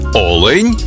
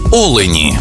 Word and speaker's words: олень: однині і в множині наголос падає на перший склад – олень та олені.